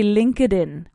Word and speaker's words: LinkedIn: Now, in English, we don't say LinkedIn, we say LinkedIn. So we nicely link, valga la redundancia, the D in linked con la I de in, but we don't say LinkedIn, we say LinkedIn, LinkedIn, right?